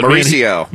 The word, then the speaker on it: Mauricio: This Maricio? Am I pronouncing it? Maricio Ruffy. I don't know how to pronounce it. But Mauricio. – Man, Mauricio! I knew. Okay, Mauricio. I'm a Moranio.